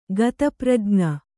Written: ಗತಪ್ರಜ್ಞ

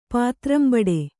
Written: ಪಾತ್ರಂಬಡೆ

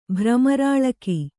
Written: ಭ್ರಮರಾಳಕಿ

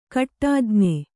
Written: ಕಟ್ಟಾಜ್ಞೆ

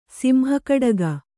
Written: ಸಿಂಹಕಡಗ

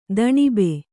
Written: ದಣುಬ

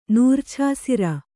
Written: ನೂರ್ಛಾಸಿರ

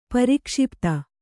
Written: ಪ್ರಕ್ಷಿಪ್ತ

